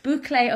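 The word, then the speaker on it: bouclé: But the textures within this trend appeal to me because I like bouchelle. Am I saying that right? – Boucle upholstery, which is that kind of teddy bear fabric, or fine knits or soft crinkly linens.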